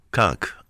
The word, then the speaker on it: conch: Conch. I mean, it's pronounced conch. It says conch.